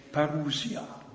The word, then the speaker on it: Parousia: Und da steht tatsächlich das griechische Wort Parousia. In Deutsch würde man es Parousie aussprechen.